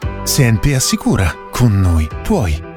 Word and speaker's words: CNP: Senpi Assicura ti offre soluzioni di risparmio, investimento, protezione e la solidità di un gruppo con 170 anni di storia. Si legge SNP, si scrive CNP.